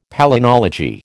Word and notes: palynology: American English

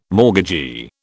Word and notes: mortgagee: British English

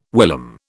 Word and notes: whilom: British English

